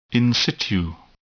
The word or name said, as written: in situ